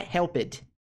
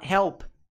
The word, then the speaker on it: helped: Não é helped. Esse som ed no final não tem. Ele só tem em situações bem específicas. A gente ainda vai falar sobre esse padrão de pronúncia pra frente. Mas ele quase não é usado. Na grande maioria dos verbos, não é utilizado ed. Por mais que esteja escrito ed, você não fala helped.